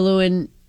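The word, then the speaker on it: Lewan: Taylor Lewin. Is that how you say his name? – Yeah.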